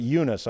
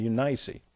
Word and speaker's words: Eunice: Or some pronounce it Eunice. I pronounce it Eunice.